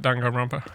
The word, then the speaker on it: Danganronpa: Danganronpa? – Yeah.